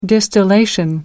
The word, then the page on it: distillation: British English